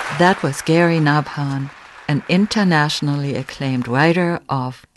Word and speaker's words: Nabhan: Nabhan is spelled N-A-B like bird, H like horse, A-N, Nabhan.